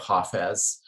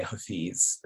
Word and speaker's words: Hafez: And so in Farsi, one would say Hafez. In Urdu, which is in South Asia, one would say Hafiz.